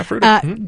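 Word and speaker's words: Fruita: I'm looking through the names right now, and we've got Melinda in Denver, Janice in Fruta. I think I'm saying that right. – Yeah, Fruta.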